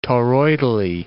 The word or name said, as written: toroidally